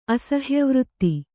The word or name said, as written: ಅಸಹ್ಯವೃತ್ತಿ